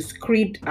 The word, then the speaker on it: Scribd: I highly recommend the Scribd app. It's spelled S-C-R-I-B-D, Scribd.